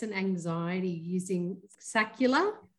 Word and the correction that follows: scalar: light. And I hope yes. I pronounced that correctly. – Yes. Hi, Tom. Thank, thank you so much. we will refer to it as scalar.